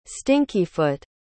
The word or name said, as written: stinkyfoot